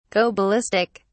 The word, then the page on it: go ballistic: British English